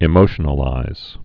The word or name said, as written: emotionalize